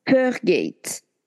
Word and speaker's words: Fergate: Et cette personne-là prononce Fergate F-E-R-G-A-T-E.